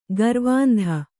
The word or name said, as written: ಗರ್ವಾಂಧ